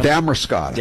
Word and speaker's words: Damariscotta: Damariscotta.